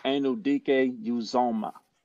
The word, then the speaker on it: Anudike-Uzomah: Felix Felix Anudike-Uzomah.